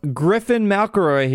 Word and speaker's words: Griffin McElroy: I have Griffin McElroy here.